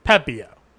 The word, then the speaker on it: Pepiot: Pepiot.